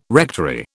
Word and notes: rectory: British English

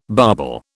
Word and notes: barbel: British English

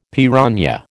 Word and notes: piranha: American English

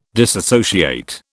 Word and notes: disassociate: British English

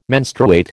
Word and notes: menstruate: American English

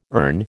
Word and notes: ern: American English

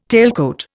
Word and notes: tailcoat: Indian

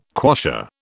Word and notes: quassia: British English

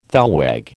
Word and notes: thalweg: American English

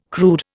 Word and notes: crude: Indian

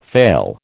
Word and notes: fail: American English

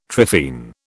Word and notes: trephine: British English

